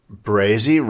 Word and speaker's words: Brazy: Jordan Brazy, rhymes with crazy, from Virginia Beach, came in second place.